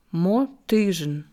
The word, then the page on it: Mozyzhin: slow